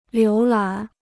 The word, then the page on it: 浏览: liú lǎn